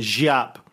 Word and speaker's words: Giap: So G-I-A-P, for people, if they think I'm saying J-A-P. No, he's not that kind of J-A-P. Jiap. I think it's pronounced Jiap. I looked it up.